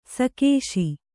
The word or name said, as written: ಸೇಕಿಸು